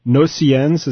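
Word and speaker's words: Gnossiennes: is this Satie's Nocillons. Is that how you say it? – Nocillons, yeah.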